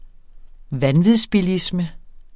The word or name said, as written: vanvidsbilisme